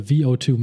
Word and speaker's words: VO2: When a person refers to VO2, whether in reference to a submaximal or maximal value of oxygen being consumed, it is correctly pronounced V.O2 because there is a dot over the V, indicating that the volume which the V represents is a 1 minute volume.